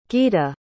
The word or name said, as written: gita